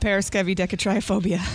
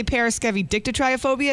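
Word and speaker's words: paraskevidekatriaphobia: Some people say periscevi dictatriophobia. It's pronounced both ways, but there's no dicta. It's deca, because deca means 10, and then tri means three. So the 10 and the 3 is the 13.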